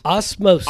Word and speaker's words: osmosis: Osmosis.